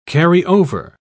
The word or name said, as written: carry-over